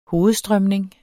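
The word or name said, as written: hovedstrømning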